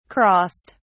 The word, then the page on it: crossed: British English